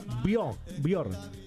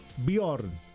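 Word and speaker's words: Björn: Acá me dice el amigo Gustavo, se pronuncia Bjorn. La J es una I. Ah, mire usted, Bjorn.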